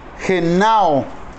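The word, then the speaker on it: genao (γεννάω): Y nacido, la pronunciación de la palabra nacido en el, en el idioma original griego, genao, con doble N, G de gato, E, N, N, A, O, acento en la A, genao, genao, que es una variante de otra palabra y significa procrear.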